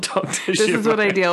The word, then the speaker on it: Zhivago: Or Zhivago, even. – Dr. Zhivago, is that how you say it? Oh, yeah. It is. I've been putting an extra I in there for years.